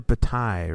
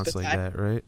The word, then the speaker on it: Bataille: It's probably Bataille, right? Because isn't Versailles... – It probably is Bataille. – It probably is Bataille, but I don't know.